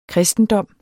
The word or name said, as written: KRISTENDOMMEN